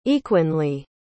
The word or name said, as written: equinely